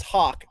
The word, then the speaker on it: Haack: The problem in that game was his name is Hawk, but it's spelled H-A-A-C-K.